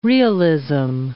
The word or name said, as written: realism